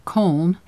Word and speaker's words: Köln: About 8 p.m., we reached Collange, or Köln, as the Germans spell it and pronounce it.